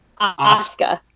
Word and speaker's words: Asuka: How do you say it? – Asuka.